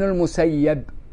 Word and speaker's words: المسيب: هو ورد اسمه رضي الله عنه ورحمه الله بتسكين بكسر الياء وفتحها، فمنهم من يقول سعيد بن المسيب في فتح الياء،